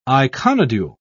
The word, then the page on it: iconodule: British English